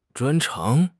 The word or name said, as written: 专长